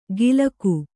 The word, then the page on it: ಗಿಲಕು: gilaku